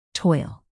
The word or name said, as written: toil